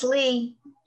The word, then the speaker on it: Leigh: It's just Leigh.